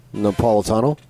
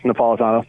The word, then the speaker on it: Napolitano: Help me out, Arizona. – Napolitano. – Yes, Napolitano. Thank you.